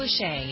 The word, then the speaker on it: Lachey: On NBC, Nick, is it Lachey? Is that how you pronounce his name? – Nick Lachey, yes.